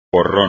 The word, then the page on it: porrón: espagnol